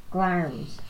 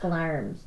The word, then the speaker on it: Glamis: We're going to Glamis Castle, which is called Glarms Castle. – Glarms? – It's pronounced Glarms. Oh. But it's written Glamis, so.